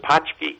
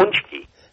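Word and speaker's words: pączki: And then the punchki, which I keep mispronouncing. I thought it was pachki. – That's what I thought. Punchki. Which are the Polish donuts.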